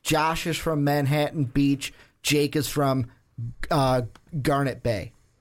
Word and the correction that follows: Granite Bay: Granite Bay. That's how you say it.